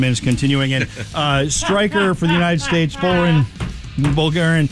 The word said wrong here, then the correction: Balogun: I said it wrong. Say it for me. Foreign. – Balogun. – Balogun? – I think so. – Balogun. Foreign Balogun.